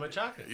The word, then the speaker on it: machaca: what? How did I say it fucked up? – Machaca. – You said machada.